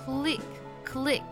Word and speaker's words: clique: 好，这个单词读作 click，我们来看这个句子。